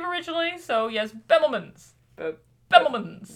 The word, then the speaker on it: Bemelmans: It's Bemelmans, but I like Bemelmans.